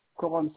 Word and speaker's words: Korom: C'est Coron Ahmad. Coron, ça s'écrit avec un K-O-R-O-M. Plus loin. Mahamat, M-A-S.